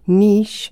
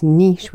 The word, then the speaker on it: niche: If you're from the UK, we borrow that word from the French, and it's niche. We pronounce it niche. If you're from the US, you will pronounce that as niche.